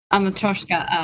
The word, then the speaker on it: matryoshka: A matryoshka.